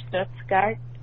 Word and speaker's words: Stuttgart: And also, in reaction to 1935, when you talked about Stuttgart, I did want to say that if you're interested in the pronunciation, is Stuttgart. In German, I study German.